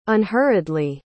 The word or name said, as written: unhurriedly